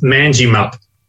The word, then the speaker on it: Manjimup: Okay, well, to start off with, I will pronounce the name of the town for you. It's Manjimup. In this part of Western Australia, there are many towns that end with the word up.